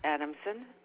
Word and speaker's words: Adamson: Yes, it is Walter Adamson, 77414.